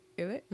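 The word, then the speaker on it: ILLIT: And in terms of like listening, I think I'm getting more into like Ilit. Ilit? Is that how you say it? – Yeah.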